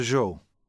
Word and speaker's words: Zhou: Mr. Zhou. – Sorry, can you spell that? – Z-H-O-U.